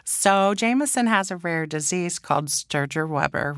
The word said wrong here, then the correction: Sturge-Weber: Sturge Weber.